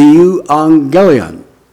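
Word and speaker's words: Euangelion (εὐαγγέλιον): euangelion. And the word is almost identical. I'll spell it with English letters. E-U-A-N-G-E-L-I-O-N. Euangelion. So the euangelistes is charged with one responsibility, and that is to proclaim the euangelion.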